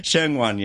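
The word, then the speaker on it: Sheung Wan: Zhuwa, is that how you say it? – Shengwan, yeah.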